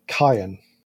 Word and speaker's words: Cain: Now, the subtitles show the name was spelt Cain, as in the Bible character Cain and Abel, but they pronounce it Kyan.